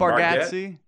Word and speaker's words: Bargatze: Nate, I don't know how to pronounce his last name. Nate Bargatze. Yeah. – Bargatze, he has a really good special on Netflix.